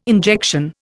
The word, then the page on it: injection: Indian